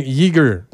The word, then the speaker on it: Yeager: Je viens, Yeager. Ça s'écrit Y-E-A-G-E-R.